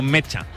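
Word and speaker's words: Nmecha: El tema es, él lo ha pronunciado como Mecha, cuando le han hecho el mítico vídeo de todos los jugadores.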